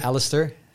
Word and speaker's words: Alistair: Alistair.